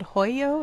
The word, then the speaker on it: Hoio: I think it's called Hoyo. It's spelled H-O-I-O. Is that how you pronounce it? – It's very good. Brava. Yes.